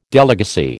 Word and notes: delegacy: American English

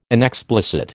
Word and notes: inexplicit: American English

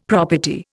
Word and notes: property: Indian

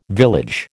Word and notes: village: American English

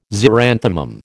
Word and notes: xeranthemum: American English

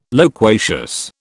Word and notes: loquacious: British English

